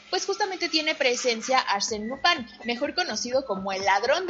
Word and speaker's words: Lupin: Es por eso que eh, tiene una pronunciación diferente. Entonces se dice Lupin.